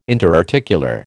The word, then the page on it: interarticular: American English